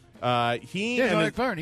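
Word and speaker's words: Vergne: I think it's Jean-Eric Verne. – Verne.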